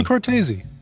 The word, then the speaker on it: Cortese: There's an E at the end, isn't there? Isn't it Cortese? Like Patrick Swayze? – I don't know.